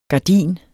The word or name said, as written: gardin